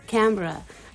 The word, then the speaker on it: Canberra: Canberra? Am I saying that correctly? – Canberra.